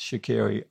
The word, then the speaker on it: Shaqiri: and you smiled then when I pronounced Shaqiri, I think correctly, at least I hope I did. – Tick in the box.